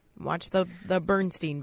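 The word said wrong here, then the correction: Berenstain: Berenstein. Berenstein. – Oh. Well, I've been saying that wrong Bernstein.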